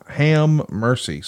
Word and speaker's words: Hammercy: Have mercy, which is spelled HAMMERCY. Ham mercy. Squish them together.